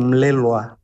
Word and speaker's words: Melelgua: Ayúdeme con su apellido, ¿cómo tengo que pronunciarlo bien? – Ya has hecho muy bien, Melelgua. – Melelgua. – Has hecho muy bien, sí.